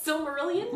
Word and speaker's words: Silmarillion: How do you say it? Silmarillion? – Mm-hmm. – Is that how you do it? Okay. – That's the next, after Quintillion, it's Silmarillion.